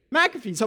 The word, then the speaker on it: McAfee: It's spelled McAfee, but we all know that you really pronounce it McAfee's, of course, right? There's an A, a silent A, a hidden A, an invisible A between the M and the C. So we call it McAfee's.